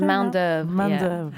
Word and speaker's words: Main d'oeuvre: And yeah, what's the name? Main d'oeuvre. – Main d'oeuvre. Yeah. – I wasn't sure how to pronounce it.